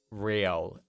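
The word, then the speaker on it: Re-L: She's Riel, R-E hyphen L.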